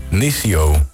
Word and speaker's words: Nesio: Nisio, denk ik. Hoe moet ik het uitspreken? – Okay. – N-E-S-I-O. – Nisio.